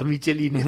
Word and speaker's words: Michelin: Pero es simpático los michelines. Porque... ¿Sí? – Hace referencia al muñequito de... – Sí, que está llena de sangre, esos michelines, porque la empresa Michelin, como se pronuncia en francés, tenía las plantaciones en Vietnam.